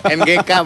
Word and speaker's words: NGK: Mereknya ngek. NGK monyong, bukan ngek. Itu NGK, dibacanya biasa aja NGK bukan ngek.